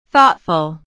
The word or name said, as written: thoughtful